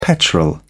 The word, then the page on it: petrol: British English